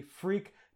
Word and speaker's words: Freq: So that's actually spelled F-R-E-Q. It's a play on frequency, which I was right, by the way. I totally called that. Just didn't know how to pronounce it properly, but it is actually Freak.